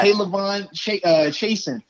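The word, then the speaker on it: K'Lavon Chaisson: Kalavon Chasen.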